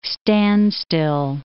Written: standstill

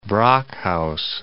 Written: Brockhouse

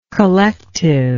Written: collectiveness

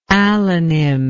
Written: allonymously

